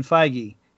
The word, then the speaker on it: Feige: there's a new addition over on Disney Plus Star Wars side, and that's Kevin Feige, if I pronounce that right.